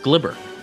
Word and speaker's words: Gleyber: Fun fact his name is pronounced Glibber.